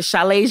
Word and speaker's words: Chalet: I have a Chalet Jean-Baptiste. I know it's a French name, but it's spelled C-H-A-L-E-T.